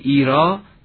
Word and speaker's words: ایرا: اما مصرع دوم این بیت، ایرا، گفتیم ایرا تلفظی کاملا کهن و مربوط می شود به فارسی میانه و فارسی باستان، ریشه هاش رو عرض کردیم، دقیقا معادل زیراست. استاد جنیدی می فرمودند همکنون این تلفظ در امثال سائره حفظ شده. چرا؟ به خصوص در توده مردم میگوین محض ارا، همون ارا یا ایرا یعنی زیرا. این تلفظ همچنان که استاد فرمودند در میان توده مردم حفظ شده است.